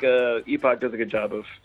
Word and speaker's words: Epoch: So these days, I work at a shop in Austin, Texas called Epoch Coffee, spelled not how it sounds. The word epic, as in a point in time, E-P-O-C-H. We pronounce it Epoch because it's not a real word, I guess.